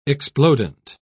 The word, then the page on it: explodent: British English